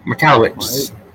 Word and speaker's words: Michalowicz: Michalowicz.